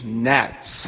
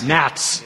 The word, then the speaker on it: NHATS: Actually, the correct pronunciation is NATS. – NATS.